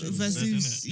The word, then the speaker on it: Verzuz: versus,